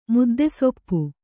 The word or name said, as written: ಮುದ್ದೆಸೊಪ್ಪು